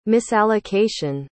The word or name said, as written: misallocation